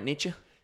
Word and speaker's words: Nietzsche: And he, along with Nietzsche, am I pronouncing his name right? Nietzsche? – Some people Nietzsche. Say Nietzsche. Some people say Nietzsche. It's fine either Nietzsche.